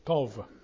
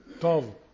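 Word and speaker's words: Tov (טוב): Y la palabra que tenemos ahí es Tov. Tov. Así se pronuncia, pero es T-O-B. T-O-B.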